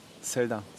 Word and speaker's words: Zelda: Wie spricht man das aus? – Zelda.